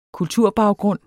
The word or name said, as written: kulturbaggrund